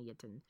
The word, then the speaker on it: Jinton: It's spelled with J, like Jana Jinton, but I'm sure it's pronounced Jana Yinton.